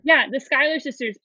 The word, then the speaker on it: Schuyler: I have seen Hamilton and It's Schuyler. – That's how her name is. Yeah, it's the Schuyler sisters, and if you look it up, it's spelt that way.